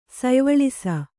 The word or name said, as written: ಸಯ್ವಳಿಸ